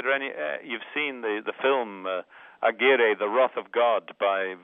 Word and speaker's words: Aguirre: Lope de Aguirre? – Yeah, Aguirre. Yeah. – Aguirre.